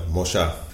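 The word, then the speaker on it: Mollá: Yeah, it's actually Moja. Because he has an accent in the A, and the L's are always pronounced like shh.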